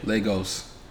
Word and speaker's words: Lagos: Lagos? – Yeah. – Lagos.